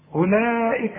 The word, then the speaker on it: أولئك: أولئك لازم تمد أربع حركات.